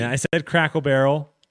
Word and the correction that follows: Cracker Barrel: Crackle Barrel? You don't even know how to pronounce it. – Crackerel. – Cracker Barrel.